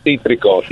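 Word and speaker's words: cítrico: You have to roll your R's in there a little bit. Citrico.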